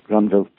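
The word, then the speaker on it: Grandville: So even when they're in England, they're actually speaking French, but it's translated into English for the balloons. That's why I always pronounce it Granville.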